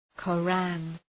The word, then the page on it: Koran: kə’ræn, kə’rɑ:n